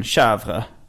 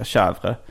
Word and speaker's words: chèvre: när Max uttalade den franska getosten chèvre ja. som det uttalas på franska, chèvre, ja. så berättade Nils honom och sa det heter chèvre. Jag stör mig på när folk säger chèvre, för ja.